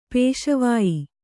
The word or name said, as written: ಪೇಶವಾಯಿ